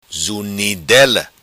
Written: Zunidela

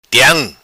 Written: Tiam

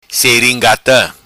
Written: Seringata

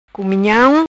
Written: Cumunham

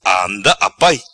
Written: Anda apai

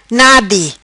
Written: Nadi

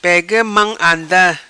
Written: Pega Mam Anda

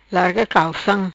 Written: Larga Calcam

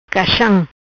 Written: Caxam

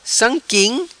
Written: sam quim

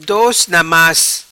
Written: Dos Na Mas

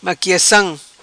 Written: Maquiacam

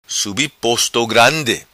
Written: Subi Posto Grandi

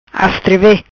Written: Astreve

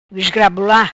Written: Esgrabula

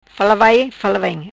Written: fala vai fala vem

